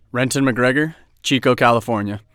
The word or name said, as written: Renton McGregor